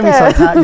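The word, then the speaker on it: Misol-Ha: Pero estoy más contento de estar con, como decimos nosotros los de Chiapas, la verdadera cascada de Misolja. – ¿Misolja? – ¿Misolja? ¿Sí? ¿Esa eres tú? ¿La verdadera cascada de Misolja? ¿Lo dije bien? – De Misolja.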